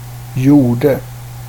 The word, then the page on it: gjorde: /ˈjʊˌrdɛ/